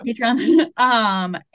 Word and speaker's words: Patreon: I haven't really done a lot of work on it in a long time, but I opened up a Patreon, is that how you pronounce it? – Yeah. – Patreon.